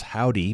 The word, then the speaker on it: Hovvdy: Still to come, we've got a duo out of Austin, Texas, who calls themselves Howdy, but it's spelled H-O-V-V-D-Y.